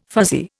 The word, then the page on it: fuzzy: Indian